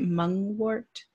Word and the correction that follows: mugwort: Mugwort? – Mugwort. – Yes.